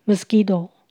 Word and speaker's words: mosquito: Mosquito 蚊子，请留意 Q U I 可以念成 g 的声音。 Mosquito。